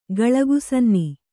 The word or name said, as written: ಗಳಗುಸನ್ನಿ